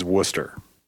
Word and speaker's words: Worcester: Now, I went to England and they told me the pronunciation in England, which is where this sauce comes from, is Worcester. – Really? – That's it. That's the pronunciation.